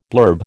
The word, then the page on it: blurb: American English